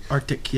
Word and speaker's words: RTIC: And you had this Arctic, and it's spelled R-T-I-C. – Arctic, yes.